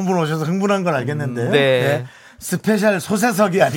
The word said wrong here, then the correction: 초대석: 아니고요. 네, 발음을 좀 정확하게. 예. 네, 스페셜 초대석이요.